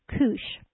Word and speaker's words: Cush: It's actually pronounced Cush.